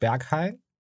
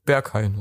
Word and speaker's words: Berghain: der heißt Berghain. Das ist richtig ausgesprochen. Nicht, dass mich die Berliner verprügeln wollen. – Nee, Berghain ist richtig.